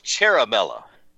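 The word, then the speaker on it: Ciaramella: So his name is Eric Cheramella, and he is a CIA spook. – It's Cheramella. – Cheramella. – Yes. – Cheramella. It's spelled the Italian way, C-I-A-R-A, and then M-E-L-L-A, Cheramella. Eric Cheramella.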